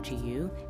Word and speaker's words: Jiyu: and the owner of Shop GU. It's spelled J-I-Y-U, and it means freedom.